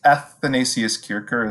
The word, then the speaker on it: Athanasius Kircher: And I see you've also written some articles about the Jesuits writ large and a few like an Athanasius Kircher, a Jesuit priest. Yeah, did I get that? Was I close? – Athanasius Kircher is how I pronounce it, but who knows?